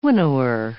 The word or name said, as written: winnower